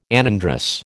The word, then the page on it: anandrous: American English